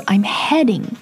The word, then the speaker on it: heading: H-I-D-I-N-G Heading H-E-A-D-I-N-G 所以它里面其实这两个单词之间发音的不同是在元音的不同上面。 So I'm heading your way 是我在朝你的方向过去。